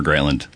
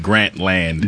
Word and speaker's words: Grantland: I didn't know how to pronounce it. I pronounce it Grantland. – Grantland. – I don't know. – No one really knows. – It's like Grantland Rice, who's a famous sports writer.